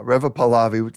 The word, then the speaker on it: Pahlavi: Reva Pahlavi, funny, it rhymes with Chalabi.